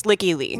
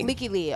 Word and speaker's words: Lykke Li: Is it just Like Lee? – It's Licky Lee. – Licky Lee.